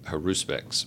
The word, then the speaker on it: Haruspex: Which you say comes from the word, I don't know if I'm going to get it right, Haruspex, is that right? – That's right. The emphasis is often on the second syllable, Haruspex, but well, you guess.